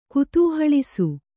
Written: ಕುತೂಹಳಿಸು

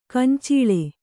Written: ಗೊಂಚಲ್

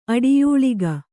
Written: ಅಡಿಯೂಳಿಗ

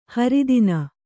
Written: ಹೆರತನ

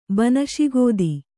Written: ಬನಶಿಗೋದಿ

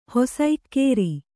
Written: ಹೊಸಇಕ್ಕೇರಿ

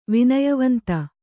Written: ವಿನಯವಂತ

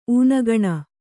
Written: ಊನಗಣ

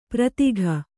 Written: ಪ್ರತೀಕ

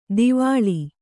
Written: ದವಳ

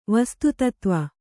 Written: ವಸ್ತುತತ್ವ

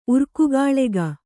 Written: ಉರ್ಕುಗಾಳೆಗ

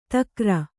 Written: ಧಕಾರ

